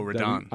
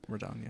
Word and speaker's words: Radahn: Oh, Radahn. I just beat. Yes, General him. Radahn.